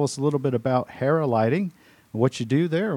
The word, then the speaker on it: Hera: We've got some great business leaders from Hera, pronounced and spelled H-E-R-A, Hera Lighting.